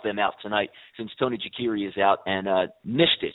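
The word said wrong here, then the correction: Stefan Nastić: Stefan Nastic, I'm sorry about that.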